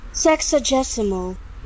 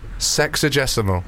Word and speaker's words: sexagesimal: Okay, for people that have been listening since the beginning, the word that I sort of pronounced incorrectly earlier, we've got it here from YouTube. – Sexagesimal. – Sexagesimal.